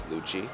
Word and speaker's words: Lucci: My name is Lucci. For those who don't know, that's spelled L-U-C-C-I.